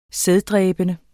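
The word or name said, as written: sæddræbende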